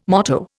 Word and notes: motto: Indian

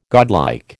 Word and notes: godlike: American English

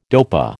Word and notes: dopa: American English

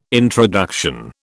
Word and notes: introduction: British English

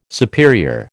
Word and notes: superior: American English

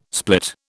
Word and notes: split: British English

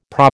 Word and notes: PRA: American English

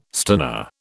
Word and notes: stunner: British English

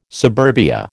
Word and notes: suburbia: American English

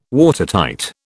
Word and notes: Watertight: British English

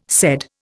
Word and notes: said: Indian

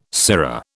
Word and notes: sirrah: British English

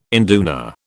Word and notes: induna: British English

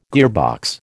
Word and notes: gearbox: American English

